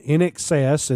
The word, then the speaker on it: INXS: He co-founded the rock band NXS, and I know I'm pronouncing that NXS like excessive.